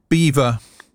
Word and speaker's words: Belvoir: I went, it's Beaver Castle, Beaver, you know. And I went, no, it's spelled Belvoir.